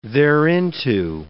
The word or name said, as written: thereinto